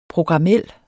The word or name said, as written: programmel